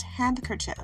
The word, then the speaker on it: handkerchief: So let me just tell you now, it is pronounced handkerchief because it's a handkerchief. But no one says handkerchief. No no no one's saying that. We say hanky. We say handkerchief. The D is silent.